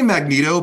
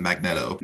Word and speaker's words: Magneto: I mean, we say Magneto, but I think a lot of people outside of America pronounce it Magneto.